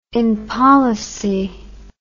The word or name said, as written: impolicy